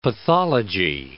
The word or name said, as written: pathology